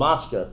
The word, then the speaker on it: Maskir: It's called Maskir. I pronounced it wrong. That's why I looked it up this morning in the pasuk.